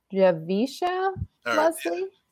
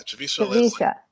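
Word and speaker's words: Javicia Leslie: And I believe her name is pronounced Javisha Leslie. – Javisha Leslie. Yeah.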